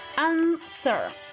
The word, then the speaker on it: answer: Silent W, la W silenciosa. Answer, answer. Como les decía, en vez de pronunciar la W, hacemos como que una pausa. Answer, answer, responder.